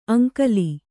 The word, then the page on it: ಅಂಕಲಿ: Kannada